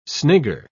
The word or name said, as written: snigger